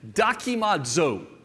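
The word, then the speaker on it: dokimazo (δοκιμάζω): And to help us to understand this word, the word is dokimazo. Now, think for just a minute. Can you see a word in our language in the pronunciation of that Greek word? I'll say it again. Dokimazo. Maybe you heard the word document.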